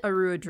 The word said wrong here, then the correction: Orodruin: Is it Orodruin? – Orodruin, yes.